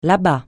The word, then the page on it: là-bas: francais